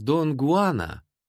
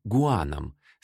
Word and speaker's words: Гуан: Пушкин, очевидно, стремился передать имя своего героя не на французский лад, а ближе к испанскому произношению — Дон Гуан. Поэтому он называет его не Жуаном, а Гуаном с придыхательным «г». Точнее было бы «Хуан».